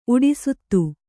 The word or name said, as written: ಉಡೆಸುತ್ತು